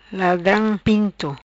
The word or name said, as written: Ladram pinto